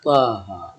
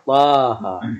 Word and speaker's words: طه: وتحذف بكثرة من طه كما تحذف طه. النطق الطه، لكن لا تجد ألفا بين الطاء والهاء كتابة إملاء، لكن في النطق تسمع الألف طه.